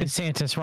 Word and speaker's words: DeSantis: Funny that the person is called, isn't he DeSantis? How do you say his name? – DeSantis, Ron DeSantis.